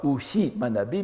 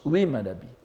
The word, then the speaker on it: ui manabi: On ne prononce pas Uhi Manabi, mais on prononce Ui Manabi. C'est-à-dire, donc, on le prononce exactement comme le I.